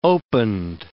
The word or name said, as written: opened